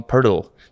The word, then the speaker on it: Poeltl: But I know that that's why Jakob Poeltl says to the NBA people, "Oh, I'm Pertl," because mm. it's there's, they, there's they no way the Americans would pronounce the umlaut, yeah.